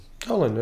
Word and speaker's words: Tallinn: I'm going to Tallinn. Tallinn. Did I pronounce correctly? Tallinn. – Tallinn, yes.